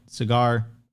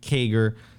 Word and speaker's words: CAGR: Cigar or CAGR or Segur, depending on how you want to pronounce it. CAGR. Thank you, Opti.